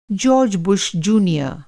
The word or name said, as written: जार्ज बुश जुनियर